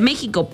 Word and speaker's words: México: Así que originalmente México sonaba más como a México. O sea, la X se pronunciaba como sh, pero con el tiempo se transformó en J. Por eso ahora se dice México, pero aún así nuestro país defendió su X como parte de la identidad y aumenta la sensación de conexión, confianza y pertenencia.